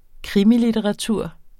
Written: krimilitteratur

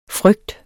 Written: frygt

